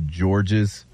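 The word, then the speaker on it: Georges: It says, the report says the running back was or is David Gabriel George's. – George. The S is silent.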